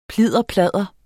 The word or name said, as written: plidderpladder